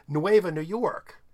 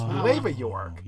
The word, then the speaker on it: Nueva York: I said, Nueva New York. I didn't hear the new. And he goes, no, it's Nueva York.